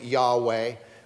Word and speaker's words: Yahweh: So we added the vowels to pronounce it Yahweh.